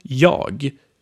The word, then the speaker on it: jag: And also jag, which means I. It is 99% of the time pronounced like ja.